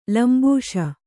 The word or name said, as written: ಲಂಬೂಷ